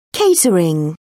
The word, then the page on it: catering: British English